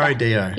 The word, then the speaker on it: Nero Dio: Nero means black. Dio means God. Black God. Nero black Dio. God. It's like two different words. Nero. That's how you can remember. – Nero Dio. Yeah. Okay. Right. – How you can remember how to pronounce.